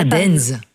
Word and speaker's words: Deinze: Et il y en a un, je m'excuse pour la prononciation, c'est Dinze. Ça s'écrit D-E-I-N-Z-E. – Ah, Dinze.